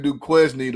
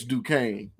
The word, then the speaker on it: Duquesne: I was saying Duquesne to be funny. It's Duquesne. – Duquesne.